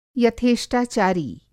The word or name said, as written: यथेष्टाचारी